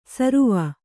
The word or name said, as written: ಸರುವ